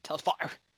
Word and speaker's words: Telfar: T-E-L-F-A-R. – F-A-R. Telfar.